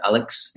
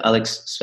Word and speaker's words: Aleks: So it's just A-L-E-K-S. So my name is spelled a little bit different. So Alex, A-L-E-K-S. And then my surname is Svetsky, S for Sam, V for Victor, E-T, then S-K-I, like ski. So Alex Svetsky.